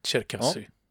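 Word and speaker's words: Cherkassi (Черкассы): Och eh, då är det ju det här med Cherkassi. Eh, uttalar jag det rätt förresten? Ja, det gör du. Cherkassi. Ja, ja vilken tur.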